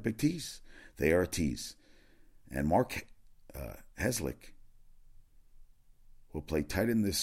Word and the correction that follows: Herzlich: Is that is that how you say it? – Mark? Yeah, I don't think that's right. Mark. Mark Herzlich, I'm sorry.